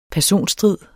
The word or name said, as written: personstrid